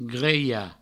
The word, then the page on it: grelhar: greia; Provençal